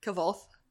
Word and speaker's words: Kvothe: Kvothe.